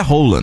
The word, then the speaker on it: Haaland: O Haaland. – Nossa, gente. – Ele é altão, né? – Que é aquilo, gente? Hein? Sabe branquinho. – A pronúncia oficial do nome dele é Rola, né? – É, então, eu fiquei sabendo, fiquei totalmente constrangida.